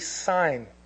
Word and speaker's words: Sin: Yeah, well, yeah, there's some variance in this, because basically some of the descendants of Ham, one of the descendants of Ham, it looks like Sin in the Bible, S-I-N, but it's actually Sign. That's how you pronounce it, is Sign.